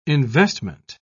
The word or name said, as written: investment